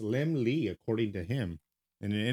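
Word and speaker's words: Laemmle: He said his name means little lamb and is pronounced as if it were spelled Lim Lee. L-E-M-L-E.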